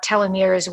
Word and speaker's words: telomeres: Telomeres, is that how you say it? – Yep, telomeres.